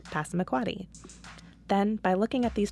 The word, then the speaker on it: Passamaquoddy: So the place you clap the most often, the qua syllable, is the primary stress, passamaquoddy. The place where you clap the next most often, the pa, is the secondary stress, passamaquoddy.